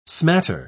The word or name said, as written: smatter